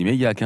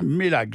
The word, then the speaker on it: Meilhac: Ça s'écrit M-E-I-L-H-A-C dans ce cas-là. – J'aurais dit Méliac, hein. – Ben oui, mais eux disent Mélac. J'ai posé la question justement à quelqu'un du département et on m'a bien dit Mélac, je respecte.